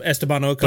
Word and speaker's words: Esteban Ocon: Yeah, I mean, Ocon. I don't know how to pronounce his name. – Esteban Ocon.